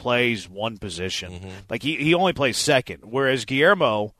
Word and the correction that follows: Guillorme: it's actually pronounced Guillorme. Yes, I knew it was weird. – Really? – But, yeah, it's Guillorme.